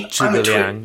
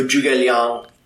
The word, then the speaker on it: Zhuge Liang: I've heard it pronounced Zhuge and Zhuge Liang, like more of a J than a Zh sound. It's always going to be a J. – Okay. – With awesome. The ZH.